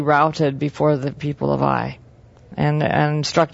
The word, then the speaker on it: Ai: Achan, they start out with Achan, and then Joshua sent men to Jericho, from Jericho to Ai, or really Ai, that's how you should pronounce it, to the east of Bethel.